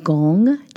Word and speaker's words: gong: And gong, G-O-N-G, you can pronounce it like lung, but with a G, that means practice, work, or skill.